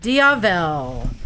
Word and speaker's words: Diavel: Oh, I thought it was Diavola. – Diavel. Ducati Diavel.